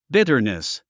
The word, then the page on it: bitterness: bítərnis; ビタァニス